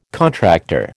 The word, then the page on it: contractor: American English